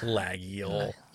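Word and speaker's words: Laguiole: And a lot of the Lagioles, they'll have the maker's name on as well. You know, their own company as well. – Lagiole. You're in France. You got to say Lagiole.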